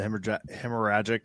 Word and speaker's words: hemorrhagic: So let's talk about EHD, the hemorrhagic. Did I say that right? – Yeah.